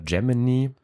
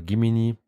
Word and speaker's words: Gemini: von OpenAI und äh, Gemini oder Gimini, je nachdem wie man es aussprechen möchte, soll halt eben genau auf diesem Level auch mit sein.